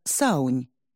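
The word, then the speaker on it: Samhain: С ирландского «Самайн» произносится как «Саунь», переводится как «Ноябрь».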